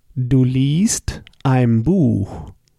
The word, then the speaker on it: liest: Du liest. You see, here we switch the vowel. Lesen with an E. Du liest with a long I that is written I-E.